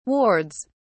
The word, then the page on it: wards: British English